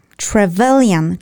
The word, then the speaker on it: Trevelyan: Second of all, that guy have that Travestarian, what was his name? – I learned to pronounce it, so I will pronounce Trevelyan, Charles Trevelyan.